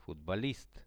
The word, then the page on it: futbolist: Russian